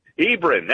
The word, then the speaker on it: Hebron: Is Hebron, Hebron, how do you say that out there? – Hebron, that's it.